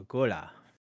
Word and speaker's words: ghulah: Yeah, well, G-H-U-L-A-H. – Oh, a ghoula.